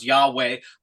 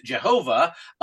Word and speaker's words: Yahweh: it would have been pronounced Yahweh, but for centuries in English circles, they pronounced it Jehovah.